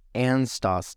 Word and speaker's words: Anstoss: Anstoss. And it's obviously German. – It's a German word, and it's spelled A-N-S-T-O-S-S.